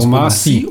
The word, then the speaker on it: Omar Sy: wie spricht man seinen Namen eigentlich aus? Omar Sy. Omar Sy. Omar Sy. Omar Sy. Mhm.